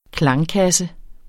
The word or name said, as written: klangkasse